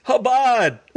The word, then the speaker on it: Chabad: One of the things that we were on the phone, and it was so funny because Victor would pronounce it wrong, and you could hear Lois in the back going, "It's Chabad."